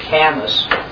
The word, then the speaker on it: Camus: Albert Camus, he's spelled like Albert Camus, but it's Albert Camus, which is pronounced a French existentialist.